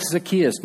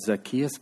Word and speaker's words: Zacchaeus: There's all kinds of people out there that are like Zacchaeus, notorious sinners.